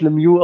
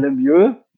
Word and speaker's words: Lemieux: Als, äh, als alter NHL-Gucker ver verbinde ich mit dem Namen, äh, ähm, gut, den spricht man nicht Lemieux aus, sondern Lemieux.